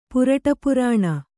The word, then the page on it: ಪುರಟಪುರಾಣ: Kannada